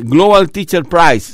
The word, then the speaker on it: Global Teacher Prize: Bueno, es Global Teacher Prize.